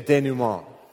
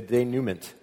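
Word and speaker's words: dénouement: And then at the end is resolution, or if you wanted to sound really snobby, you would say it's the denouement, right?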